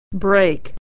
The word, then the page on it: break: e-ey